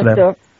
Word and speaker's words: Butler: Non so come si pronunci bene in americano. – Certo, Butler. Judith Butler, sì.